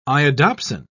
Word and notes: iodopsin: British English

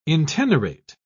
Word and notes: intenerate: British English

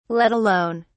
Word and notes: let alone: British English

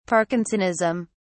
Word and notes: parkinsonism: British English

